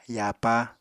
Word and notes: yapa: Apatani

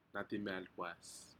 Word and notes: nadimi alpas: Aneityum

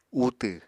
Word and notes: utw: Apatani

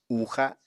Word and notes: uuxa: Apatani

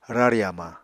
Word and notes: rari hamañ: Apatani

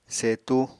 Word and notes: setu: Apatani

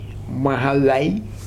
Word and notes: mahavae: Futuna

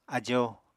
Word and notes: ajo: apatani

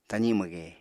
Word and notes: tazi mwge: apatani

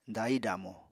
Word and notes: dahi-damo: apatani

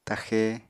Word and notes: taxe: apatani

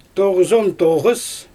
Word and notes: toɣɯzontoɣɯs: Xyzyl

